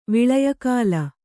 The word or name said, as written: ವಿಳಯಕಾಲ